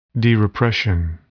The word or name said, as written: derepression